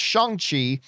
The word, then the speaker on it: Shang-Chi: just wanted to let you know that Shang-Chi is apparently pronounced Shang-Chi as per lead actor Simu Liu tweet advising people how to say it.